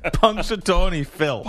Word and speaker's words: Punxsutawney Phil: it's Punxsutawney Phil.